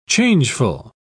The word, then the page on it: changeful: British English